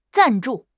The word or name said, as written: 赞助